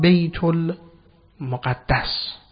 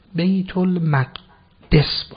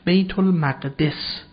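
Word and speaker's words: بیت‌المقدس: اون سرزمین مقدس رو ما میگوییم الان بیت المقدس، که شاید صحیح این کلمه بیت المقدس نباشه، بیت المقدس باشه. تلفظ درست این کلمه بیت المقدس. حالا علتش چیه؟ چون بیت اینجا ترکیب، بیت مضاف اضافه میشه به بعد، ترکیب وصفی نیست، صفت و موصوف نیست. اگر بگیم بیت المقدس وصف میشه بعد غلطه، این اضافه باید بیت المقدس باشه دیگه.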